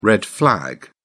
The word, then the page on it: red flag: British English